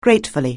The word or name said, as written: gratefully